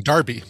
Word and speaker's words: Derby: Derby. – Derby. – Even though it's spelled Derby, but pronounced Derby. – It is Derby.